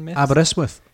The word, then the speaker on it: Aberystwyth: Aberystwyth.